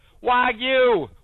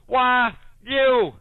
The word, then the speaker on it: Wagyu: Oh, for the love of God, Grace has gone on a rant about Wagyu beef for three straight days now. Do you think she could look it up just once? Wagyu! Wagyu!